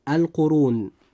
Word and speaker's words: القرون: كلمة القرون جاء حرف الراء هنا مضموما، فيجب أن يفخم وينطق هكذا: القرون.